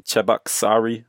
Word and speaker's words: Cheboksary: And right now you're in Cheboksary, Russia.